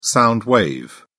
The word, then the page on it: sound wave: British English